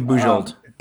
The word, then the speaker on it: Bujold: Genevieve, is that how you pronounce her first name? – Genevieve Bujold. – Bujold.